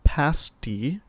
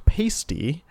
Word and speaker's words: pasty: and is wondering if we could cover pasty shops, pronounced pasty. – Thank you. Not pasty, as my favorite religion professor once said, it's a pastry, not a nipple tassel.